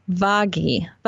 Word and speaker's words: Vaghi: We have the who's it by Monsignor Peter, and I wrote down how to say it, Vaghi. There you go, V-A-G-H-I, Vaghi.